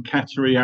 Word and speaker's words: Qatar: So it depends on where you come from in the Gulf. So in Qatari Arabic, it's more Qatar with a G rather than a Q.